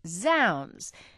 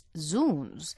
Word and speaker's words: zounds: Any self-respecting Shakespearean actor, he writes, will tell you this exclamation comes from the phrase God's wounds, and it's pronounced zounds. But alas, we did some digging and found that even in Shakespeare's time, both pronunciations were used. Today, the pronunciation zounds is preferred, though in some Shakespeare productions, you may still hear the occasional zounds.